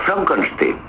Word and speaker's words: Frankenstein: No, se pronuncia Frankenstein.